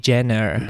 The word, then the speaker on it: Jenner: ¿cómo se pronuncia esta señora? Kylie Jenner. Gracias.